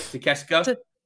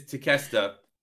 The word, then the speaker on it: Takeshita: Did I say that right? Takeshita? – No, close enough.